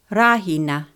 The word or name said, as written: Rahina